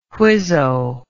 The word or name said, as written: whizzo